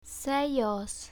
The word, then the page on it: sellos: spanish